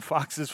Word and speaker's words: Faux: And I knew a guy in school, his last name was Fox, F A U X, and that's how he pronounced it was Fox. So uh, that's what I'm going to go with.